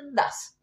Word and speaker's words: DAZN: Dazen, Dazen, Dazen, D-A-Z-N. – D-A-Z-N. Das, digámosle Das. Das. Das. Más fácil.